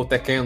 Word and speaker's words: Tekken: Acho que ele saiu depois do filme do Tekken. O Tekken também tem. Ou Tekken, não sei qual que é a pronúncia em si. Nossa, é Tekken.